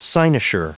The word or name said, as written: cynosure